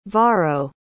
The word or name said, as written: varro